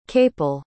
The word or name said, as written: Capel